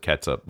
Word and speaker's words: ketchup: I'm all the way on the side of K-E-T-C-H-U-P and pronouncing it ketchup. I've seen it spelled that way, and I've heard people pronounce it catsup before. I think of that as more of an old-timey, old-fashioned, like ye old England-y way of saying it. Like you'd spell old, O-L-D-E, you know, that kind of deal.